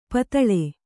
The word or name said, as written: ಪಿತ್ತಿಲ್